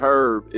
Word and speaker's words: herb: tell Talisha today you learned that the H in Herb is silent. – Yeah, he's gonna say something. – She kept saying Herb. I said, Polo, you know the H is silent, right? – I'm so glad you told her that.